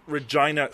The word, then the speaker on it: Regina: I'm Derek Stoffel, and I'm from Regina, Saskatchewan. Don't laugh, that's how we pronounce it.